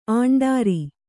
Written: ಆಂಡಾರಿ

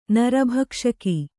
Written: ನರಭಕ್ಷಕಿ

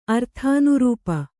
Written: ಅರ್ಥಾನುರೂಪ

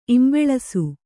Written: ಇಂಬಳಿಸು

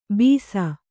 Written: ಬೀಸ

